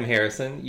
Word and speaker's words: Harrison: I'm Harrison.